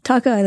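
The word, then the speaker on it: Tokaji: Tokai like Hawkeye Pierce.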